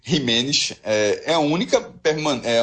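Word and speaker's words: Jiménez: Ou seja, essa, essa, João, só essa, essa questão de Jimenez, é Jimenez, né? É. Essa pronúncia espanhol.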